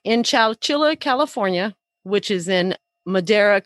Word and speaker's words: Chowchilla: On July 15th, 1976, in Chowchilla, and I did look that up to see how you say it exactly. It is Chowchilla. Not chinchilla.